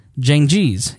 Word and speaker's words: Cengiz: last year, visited the consulate in Istanbul, Turkey, to collect a document he needed in order to marry his Turkish fiance, Hatice Seng, Cengiz. Yeah, there we go.